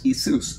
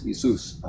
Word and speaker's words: Iesous: The Greeks wouldn't have said Yeshua. They would have said Iesous.